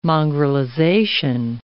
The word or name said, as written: mongrelization